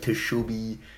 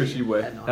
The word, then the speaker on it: Tshiebwe: I thought it was like Tshiebwe. – Oscar Tshiebwe. That's it.